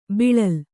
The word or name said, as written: ಬೀಳಿಲು